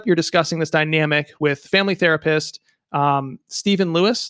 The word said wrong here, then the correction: Stevan: Stevan. Stevan. He pronounces it Stevan.